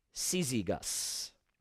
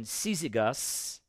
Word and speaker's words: Syzygus: So pronounce something like Syzygus. Syzygus.